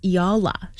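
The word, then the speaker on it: Eala: I was corrected today. It's Alex Iala.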